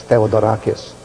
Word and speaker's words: Theodorakis: His name is spelled Theodore, and then drop the final E and just add A-K-I-S, Theodorakis.